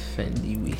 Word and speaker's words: Thandiwe: Oh yeah, I forgot. Thandi's name is is is pronounced like that. – Thandiwe. Mm.